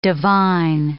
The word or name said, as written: divine